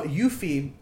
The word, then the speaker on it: eufy: That's just no, Yuffie, we named him Eugene. It's spelled E-U-F-Y.